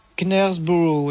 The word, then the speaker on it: Knaresborough: C'est Knaresborough, c'est ça ? – Knaresborough, oui. – Voilà, Knaresborough, donc le K se prononce pas.